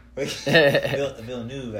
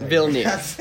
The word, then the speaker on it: Villeneuve: Villeneuve, I believe. – Is Villeneuve. Villeneuve, guys. That's how you say it.